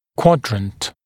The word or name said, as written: quadrant